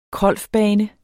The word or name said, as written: krolfbane